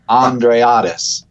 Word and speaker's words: Andreatis: But I think the best way to remember it is that it's just like the man's name, Andre. And then the first 2 syllables of the word Odyssey. So, Andreatis.